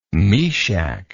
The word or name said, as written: meshach